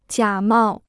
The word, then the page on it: 假冒: jiǎ mào